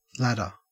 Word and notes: ladder: Australian English

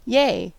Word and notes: yay: American English

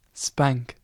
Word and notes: spank: British English

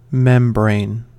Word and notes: membrane: American English

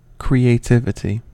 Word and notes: creativity: American English

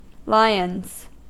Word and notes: lions: American English

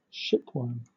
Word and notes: shipworm: British English

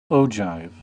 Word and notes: ogive: American English

